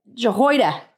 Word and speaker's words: Jehoiada: Then, how do you say his name? Jehorid? – Huh? The, Jehoiada? – Jehoiada. Jehoiada. Okay.